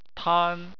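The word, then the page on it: tan: tone 1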